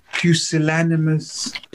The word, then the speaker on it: pusillanimous: Question to ask you is that are you familiar with the phrase pusillanimous pussyfooting? – Wait, sorry, you said cusillamonous. I said that right. – No, pew, like in a church.